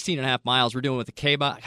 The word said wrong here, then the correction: Kaibab: How do you say that again? I said it wrong. – He's going down south, Kaibab, and up Bright Angel.